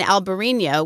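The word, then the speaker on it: Albariño: Alvarinho is the Portuguese spelling and pronunciation with a V like Victor, and Albarino with a B like boy is the Spanish spelling and pronunciation.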